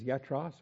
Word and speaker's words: iatros: Yatros, starts with an I. So you pronounce it with a Y sound, like Jesus and Yaakov and all the other Greek words that start with I. Yatros. I-A-T-R-O-S, used 7 times in the New Testament,